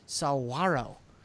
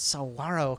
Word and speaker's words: saguaro: We're going to go do night photography out in the desert with I've been informed how to say this, saguaro. There's no G. It's saguaro cactus.